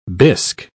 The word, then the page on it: bisk: British English